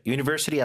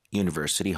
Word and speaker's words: university: 자, university 앞에 U는 모음 같지만 실제 발음이 U, 반모음이어서 앞에 관사가 언이 아니라 어가 맞습니다. A university hospital, 그것도 잘 챙겨주시고.